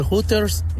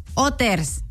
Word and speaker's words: Hooters: Se dice Otters. – Hoy no más. ¿No? – Bueno, pues sí, ¿dónde? Porque la H es muda. Otters.